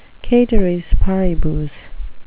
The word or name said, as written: ceteris paribus